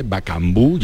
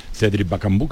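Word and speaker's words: Bakambu: Bacambú, ya te lo puedo además eh, eh, confirmar, como si fuera con acento en la U, Cedric Bacambú, que se ha sentado en primera fila y comienza el acto, eh, el DIRCON del Real Betis Balompié.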